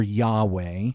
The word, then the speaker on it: Yahweh: We pronounce it often either Jehovah or Yahweh.